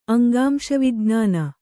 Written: ಅಂಗಾಂಶವಿಜ್ಞಾನ

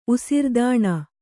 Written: ಉಸಿರ್ದಾಣ

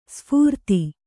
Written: ಸುಫರ್ದು

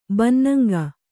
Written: ಬನ್ನಂಗ

